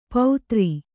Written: ಪೌತ್ರಿ

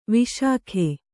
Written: ವಿಶಾಖ

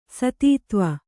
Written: ಸತೀದೇವಿ

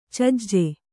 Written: ಚೆಜ್ಜ

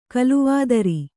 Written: ಕೋಲಾವತಾರ

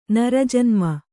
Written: ನರಜನ್ಮ

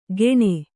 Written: ಗೆಣೆ

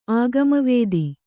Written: ಆಗಮವಿದ

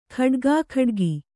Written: ಖಡ್ಗಾಖಡ್ಗಿ

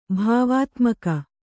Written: ಭಾವಾತ್ಮಕ

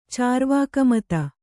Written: ಚಾರ್ವಾಕಮತ

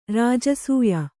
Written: ರಾಜಸೂಯ

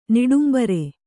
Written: ನಿಡುಂಬರೆ

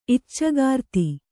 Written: ಇಚ್ಚೆಗಾರ್ತಿ

